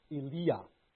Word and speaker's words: Elijah: Names should be pronounced as Eliah.